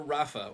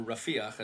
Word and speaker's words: Rafah: Yeah, waiting for Rafah, or Rafiach, as we pronounce it here in Israel, waiting for Rafiach has become a theme.